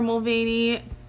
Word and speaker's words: Mulvaney: Instagram, Twitter, TikTok, Tumblr, all the social medias, at Parker Mulvaney.